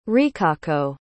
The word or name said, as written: rikako